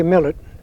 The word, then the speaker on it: Millet: Millett. – And we spell that last name? – M-I-L-L-E-T.